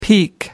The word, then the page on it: peek: American English